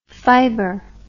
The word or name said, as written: fibr